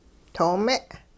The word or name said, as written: too-me'